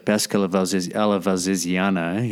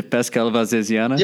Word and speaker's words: pesca alla valsesiana: And in Italy, of course, you had the pesca alla valsesiana, if I'm pronouncing that correctly. – Yeah, perfect. – Was that okay? The pesca alla valsesiana? – Yeah, well, bravo, bravo.